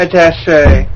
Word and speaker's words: attaché: Is that how you pronounce it? A-T-T-A-C-H-E with an apostrophe over it. Is that a press attaché? – Attaché.